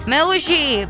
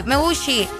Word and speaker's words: Meucci: Eh, la persona que lo creó fue Antonio Meucci. Espero yo estar pronunciando bien su apellido. – Meucci. Meucci, Y él mismo fue que lo bautizó con el nombre de teletrófono.